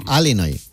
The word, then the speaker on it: Illinois: Se pronuncia Illinois. – Sí, perdón. Sí. – Illinois. – Pues yo digo Illinois, si no, entendemos todos. Porque como diga Illinois, nadie lo entiende.